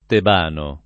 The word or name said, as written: tebano